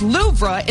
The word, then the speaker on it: Louvre: The weight loss drug is high on the list, along with Storm Eowyn and the Louvre Museum, which is pronounced Louvre in France.